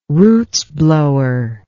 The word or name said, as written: roots blower